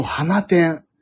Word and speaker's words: 放出: あ、なんだっけ昨日タクシーでちょっと聞いたのが、こっちの方でさ、放出放出って書いて、全然違う読み方するんだよね、確かね。そうそう、花点。すごくないですか、それ。なんでそう読んじゃったの最初の人。花、放出って書いて花点って言うんですって。